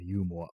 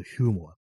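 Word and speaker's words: humour: ね、ユーモア。英語で、英語の発音だとヒューモアっていうね、 H、ね、 H を発音するっていう、感じですよね。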